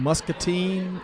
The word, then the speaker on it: Muscatine: So the company is based in Muscatine, Iowa. – Did I say that right? – Mm-hmm.